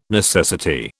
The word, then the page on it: necessity: British English